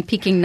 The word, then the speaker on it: Pekin: And you're saying Peking, not pecan, the nut. – It's spelled P-E-K-I-N, but it meant Peking at the time.